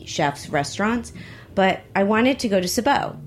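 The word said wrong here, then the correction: Seiobo: So am I saying that right? – Sayobo. – I wanted to go to Sayobo.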